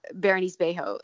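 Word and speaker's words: Bérénice Bejo: But one thing I do want to say about the film that I appreciated was Berenice Bejo.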